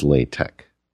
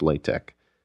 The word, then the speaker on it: LaTeX: LaTeX is LaTeX. And I should have looked that up before the show, but I've just had a lot going on, and frankly, I didn't have time to get the proper pronunciation. So it's LaTeX.